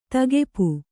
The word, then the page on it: ತೆಗೆಪು: kannada